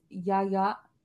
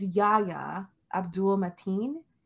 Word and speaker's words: Yahya Abdul-Mateen: His name is Yahya. It's Yahya Abdul-Mateen.